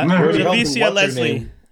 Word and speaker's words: Javicia Leslie: Uh, Javicia Leslie. Javicia uh, Leslie. Uh, the, the, the, girl taking over uh, the, the mantle. – Yeah, Javicia Leslie.